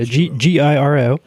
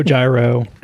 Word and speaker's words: Giro: Um, uh, for the entirety of the time that I was employed there, close to a decade, um, nobody really cares how you say it as long as you're thinking about them. so Giro, Gyro, Giro, nobody cares. – They're all the same.